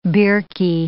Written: birkie

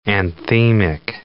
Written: anthemic

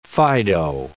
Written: fido

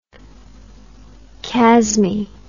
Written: chasmy

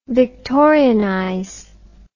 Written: Victorianize

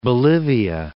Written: Bolivia

